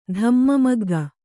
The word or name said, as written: ಧಮ್ಮಮಗ್ಗ